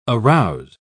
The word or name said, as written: arouse